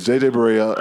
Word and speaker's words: Barea: Anyways, J.J. Barea. – I bet ain't how you say his name.